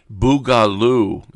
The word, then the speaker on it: boogaloo: Boogaloo is how it's pronounced.